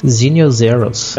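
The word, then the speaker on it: Xenial Xerus: Vamos ver o que, que ele diz. Zinio Zeros.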